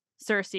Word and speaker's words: Circe: And I should say, I'm sorry, I don't say Circe. I probably should, but everyone knows her as Circe in English. But I do know that in Greek, it's Circe. So you feel free to pronounce it however you want. But, and I like to tell my listeners that it is Circe, but it just, you know, anyway, it's interesting.